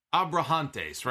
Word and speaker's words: Abrahantes: So Penta has pretty much, they've given him Alex, how do you pronounce it? Alex Abrahantes, right? That's how you pronounce his last name.